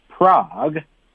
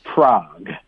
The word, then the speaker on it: Prague: Prague. – 好啦,来,城市名字啊,城市名字咱们英文学起来。P-R-A-G-U-E. – Prague. – Prague. – Yeah. One syllable.